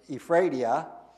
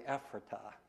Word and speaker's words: Ephrata: What helped me was in the Lancaster Intelligencer, they actually spelt it out, how you should pronounce it, L-A-N-K-I-S-T-E-R, so Lancaster, instead of Lancaster. And I used to think I lived in the city of Ephrata, and learned that it was actually Ephrata.